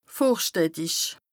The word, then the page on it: vorstädtisch: Bas Rhin